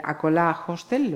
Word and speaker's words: Acolá: Camilo Garrido, propietario de Acola Hostel, ¿lo digo bien? – Sí, sí, perfectamente.